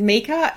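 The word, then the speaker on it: Mieke: So, yeah, my name's Mika. It's a very strange name. It's mispronounced and misspelt all the time.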